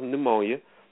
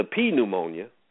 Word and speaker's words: pneumonia: Dress yourself in white clothes so you don't catch the pneumonia or the P pneumonia. Sometimes you got to pronounce the P. Sometimes you got to pronounce the P, man.